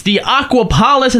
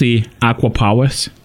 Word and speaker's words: Aquapolis: The Aquapolis has been added. – Excuse me. It's pronounced the Aquapolis.